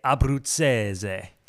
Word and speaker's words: Abbruzzese: We say Abbruzzese.